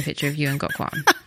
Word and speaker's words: Gok Wan: I met Gok Wan.